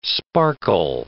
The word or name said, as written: sparkle